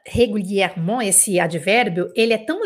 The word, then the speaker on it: régulièrement: Tem quatro es, esse advérbio régulièrement. E cada um desses es, por conta do acento, por conta da sílaba, ou por conta da nasal, ele tem um som diferente.